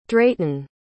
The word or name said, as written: drayton